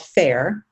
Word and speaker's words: FAYR: You guys already heard the commercial, I'm sure, in the middle of this episode, but it's called FAIR, but it's spelled F-A-Y-R.